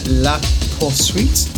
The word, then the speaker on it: La Poursuite: La Poursuite. Is that how you pronounce that? – Yeah.